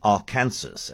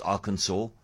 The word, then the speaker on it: Arkansas: Debbie saying, I used to say Arkansas, as in Kansas. It's only when I heard it mentioned on the news that I learned how to pronounce it, and this was at the ripe old age of 60. I presume most people know that it's Arkansas, even though it's spelt Arkansas, but if you didn't know that, now you do.